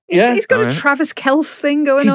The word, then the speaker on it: Kelce: It's Travis Kelce.